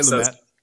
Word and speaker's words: Lumet: Is the Lumet the pronunciation? Yeah. Okay. – Some people say Lumet. – Lumet. Okay, so it depends on whether you're going with the French or the English.